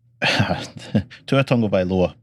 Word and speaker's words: Tagovailoa: And it's Tua Tagovailoa, but you don't spell it like you say it. So it's Tua is T-U-A, and then Tagovailoa is T-A-G-O-V-A-I-L-O-A, and then stretchered off after severe head injury doctor explains.